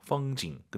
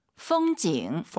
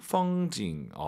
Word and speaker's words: 风景: 风景 올라간 순간 엄밀히 말씀드리면 틀린 발음이에요. 뒤에가 사성이잖아요. 반삼성이죠. 风景 끝나야 됩니다. 풍경이 중국어로 风景. – 风景.